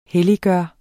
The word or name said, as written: helliggøre